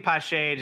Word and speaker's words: Pache: People saying Pache. I like saying Pache just because it rhymes with cachet and it flows a little better.